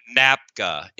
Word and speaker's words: NAPGA: So Western Bounty, which is Desiree Stark, said that, how do you call it? NAPGA? How do you say that? – NAPGA, N-A-P-G-A.